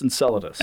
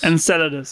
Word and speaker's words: Enceladus: I believe it's pronounced Enceladus. – Enceladus, yeah.